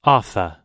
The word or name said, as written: offa